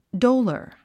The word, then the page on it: dolor: dóulər; ドゥラァ